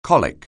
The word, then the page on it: colic: anglais